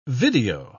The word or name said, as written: video